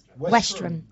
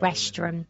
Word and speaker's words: Westerham: Westerham. – There we go. Thank you. Westerham.